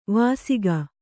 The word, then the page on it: ವಾಸಕ: kannada